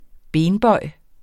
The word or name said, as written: benbøj